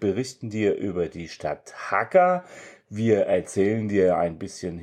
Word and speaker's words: Jaca: Jaca schreibt sich J-A-C-A. Wir haben gelernt, dass das J als H ausgesprochen wird, relativ hart.